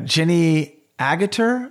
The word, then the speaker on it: Jenny Agutter: So to round out the cast, it's uh, Jenny Agutter, I believe is the pronunciation, and she plays nurse Alex Price.